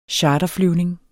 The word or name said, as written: charterflyvning